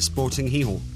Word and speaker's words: Gijón: That's how you say it, Sporting Gijón.